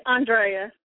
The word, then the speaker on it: Ondrea: Yeah, Ondrea.